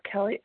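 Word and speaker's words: Kelley: Hey, guys, it's Kelly S., recovered compulsive eater in Tulsa, Oklahoma.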